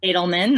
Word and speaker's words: Adelman: Did I say that correctly, Brenda? – Adelman, not bad though. – Okay, Adelman, okay.